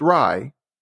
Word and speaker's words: RIE: And foundations of RIE, pronounced Rye, they empower parents across the globe to show up and grow up alongside their kids when it comes to navigating daily conflict.